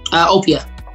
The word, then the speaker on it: Opia: Opia.